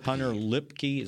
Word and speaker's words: Luepke: Hunter Lipke. – Is that how you say it? – Yes.